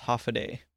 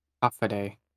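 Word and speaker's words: Håfa adai: And then on Guam, it's hafaday. – Half a day. – Yeah, there you go.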